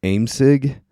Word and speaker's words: AIMSIG: They pronounce it AIMSIG. They drop the O from the object. It's called AIMSIG.